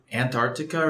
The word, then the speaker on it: Antarctica: See, like, American accent, it's like Antarctica, but some people say Antarctica. Like, there's a C in it.